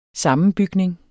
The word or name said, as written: sammenbygning